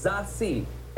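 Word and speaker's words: Zazie: I want to make sure I got the pronunciation. It's Zassi. – That's perfect.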